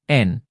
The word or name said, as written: n